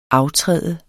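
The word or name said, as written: AFTRÆD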